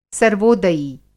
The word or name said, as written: सर्वोदयी